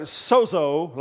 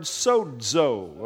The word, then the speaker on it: sozo: Now, when you see a Greek word written like this, it looks like sozo, like bozo. It's not, though. It has to be pronounced with a D before that Z, okay?